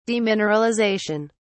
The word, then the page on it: demineralisation: British English